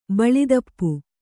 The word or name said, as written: ಭುಲತಾಪಿ